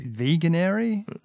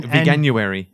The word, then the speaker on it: Veganuary: I think it was Stuart Bond asking how you pronounce veganary? Vegan. Like, I think it's vegan. – Veganuary. – Yeah. Oh, yeah. That's how I would pronounce it. – I would say veganuary.